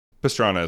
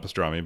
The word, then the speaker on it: Pastrana: Travis Pastrana, Bucky Lassick. I mean, is that how you say it? Yeah. – Pastrana as well, not Pastrami, but yeah, good try.